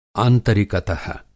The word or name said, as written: आंतरिकत: